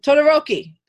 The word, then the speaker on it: Todoroki: Todoroki.